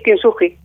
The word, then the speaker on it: Kintsugi: ¿Lo digo bien, Kintsugi? – Sí, Kintsugi.